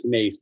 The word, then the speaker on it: Nace: It's Max Nace, like a long A sound.